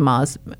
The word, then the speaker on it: Maz: You know, I was very happy, for example, today, when I'm talking to William Mays, Maz. I think it's pronounced Maz. M-A-Z. Maz. I would pronounce it Maz because I think it's much longer. So William Maz, M-A-Z, and the Bucharest Dossier.